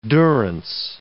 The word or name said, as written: durance